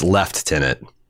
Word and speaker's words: lieutenant: Dwayne the Rock made, Johnson Jr. Yes, Dwayne the Rock Johnson Jr. is made a lieutenant for scoring a point on her uh, Isildur. – I'm sorry, you mispronounced that. It's left tenant. – Lieutenant. I'm never going to say it that way.